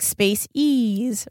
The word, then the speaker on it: Space Ease: And now I go to Space Ease. Am I saying it right? – Mm-hmm. – And it's like Space Ease, right? – Yes, yeah. Yes.